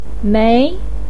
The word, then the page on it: mei2: pinyin